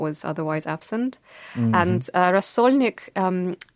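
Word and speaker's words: rasolnik (рассольник): rasolnik.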